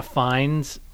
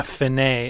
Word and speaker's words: Fine: I should add one recurring joke I thought of in this screenplay is that people are always pronouncing Donna Fine's last name as Finney. So they're like, Donna Finney, and she's like, it's fine.